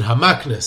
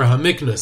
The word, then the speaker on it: HaMikna: And I got many corrections on how to pronounce his Sefer's name of Sefer HaMikna. And I think I incorrectly pronounced it HaMakna. So here it is, Sefer HaMikna.